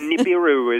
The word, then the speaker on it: Nibiru: Is it Nibiru, Nibiru? – Well, I think that's a case of potato, potato, but Nibiru is what I usually use.